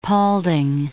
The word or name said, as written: Paulding